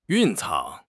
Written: 蕴藏

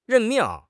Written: 任命

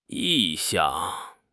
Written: 意向

